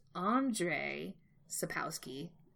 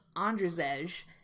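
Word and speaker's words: Andrzej Sapkowski: And I, so my person read the first book called Blood of Elves by, which I just found out his name is pronounced Andre Sapowski, which I thought it was Andrzej. And for, like, 2 years, that's how I've been pronouncing it.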